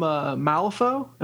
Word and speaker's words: Malifaux: Yeah, all from Malifaux. Am I saying that right? – Yeah.